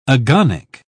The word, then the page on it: agonic: British English